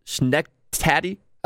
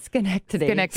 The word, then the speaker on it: Schenectady: Schnecktady.